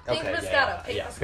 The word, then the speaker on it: Moscato: Yeah, Pink Moscato.